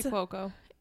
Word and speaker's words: Cuoco: Kaylee Cuoco.